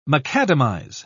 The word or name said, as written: macadamize